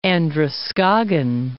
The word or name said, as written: Androscoggin